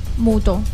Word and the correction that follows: muito: Portugués se dice muito, muito, muito, muito, muito, muito, muito, muito, muito, mucho se dice muito.